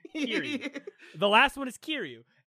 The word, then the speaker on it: Kiryu: And then the last dude is Kairu. And so you, like, get Kiryu.